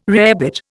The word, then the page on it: rarebit: Indian